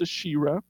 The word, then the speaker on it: Asherah: And I'll also say Asherah because I don't like to say Asherah, but it's properly pronounced Asherah.